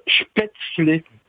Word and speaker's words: Spätzle: Ouais, ça se prononce spätzle.